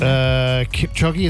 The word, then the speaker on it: Kipchoge: Kipchoge, is that how I say his name? – Yeah.